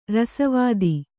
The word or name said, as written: ರಸವಾದ